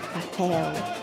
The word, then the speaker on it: papel: Papel, papel. So yeah, so we stress the e and then we say u. Papel, papel. So the words that end in L are always pronounced as an U.